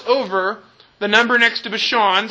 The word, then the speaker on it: Bashan: That's how you pronounce it in Hebrew, Bashan.